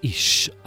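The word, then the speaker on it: Ish (אִישׁ): The man in Hebrew is Ish, I-S-H, phonetically. Female is Isha, I-S-H-A.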